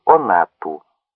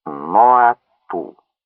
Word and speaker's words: ноа-ату: Их характеризуют два таитянских слова — «я орана» — «здравствуй», «до свидания», «спасибо» и прочее, и «онату» — «плевать, не все ли равно» и прочее. И это их называют дикарями. Собственно, не «онату», а «ноа-ату» произносится «ноа-ту».